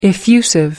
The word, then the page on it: effusive: American English